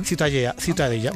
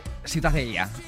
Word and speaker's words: Ciutadella: Para mí la Ciutadella es... ¿cómo se pronuncia en La Ciutadella. – Ciutadella. Bueno, pues Ciudadela también en castellano.